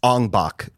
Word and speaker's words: Ong Bak: Ong Bak, the Thai Warrior, which is a really, really amazing martial arts movie.